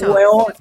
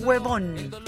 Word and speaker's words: hueón: Es hueón. – Sí, hueón, o sea, no es huevón con B.